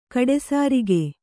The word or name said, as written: ಗಡಿಸರಕು